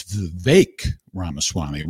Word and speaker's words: Vivek Ramaswamy: It's Vivek Ramaswamy, rhymes with bake, Vivek Ramaswamy.